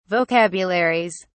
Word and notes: vocabularies: British English

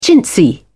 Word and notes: chintzy: British English

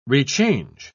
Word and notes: rechange: British English

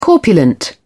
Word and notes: corpulent: British English

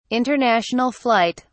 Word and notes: international flight: British English

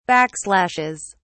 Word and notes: backslashes: British English

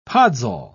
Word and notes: podsol: British English

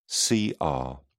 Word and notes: cr: British English

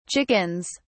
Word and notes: chickens: British English

